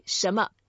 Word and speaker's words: 什么: We say 什么. That's it. – 什么. Do you pronounce the N or do you just kind of glaze over it? – We just make it light. – 什么.